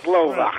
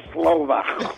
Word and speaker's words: Slovak: And you got to say it like that, Slovak. Like your hawk spitting Slovak.